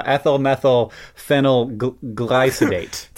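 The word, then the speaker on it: ethyl methylphenylglycidate: I don't even think I said it right. Ethyl methyl phenyl glycidate. – Today's podcast is brought to you by ethyl methyl glycosidylate.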